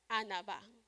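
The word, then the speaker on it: anava (ענווה): And I made the iPad talk so I can know how to say it, exactly how they say it. It's anava. I put on anava.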